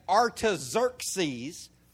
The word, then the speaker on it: Artaxerxes: But that's Artaxerxes is how you pronounce that.